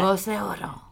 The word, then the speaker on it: la voz de oro: La voz de oro.